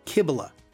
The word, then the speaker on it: Cybele: Today's lesson, we'll look at the main goddess of the city, Kybele. That's spelled C-Y-B-E-L-E.